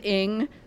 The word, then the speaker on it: Ng: The Celeste Ng, everything I... Yeah, she pronounces it Ng. In fact, her Twitter handle is pronounced Ng. but it's N-G is how she spells it.